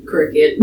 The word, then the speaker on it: Cricut: But, yeah, no, it's pronounced Cricut.